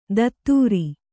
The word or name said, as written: ಧತ್ತೂರಿ